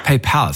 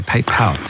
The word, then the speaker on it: PayPal: PayPal, sorry, vergesse ich das mal, dass ich es falsch ausspreche. PayPal.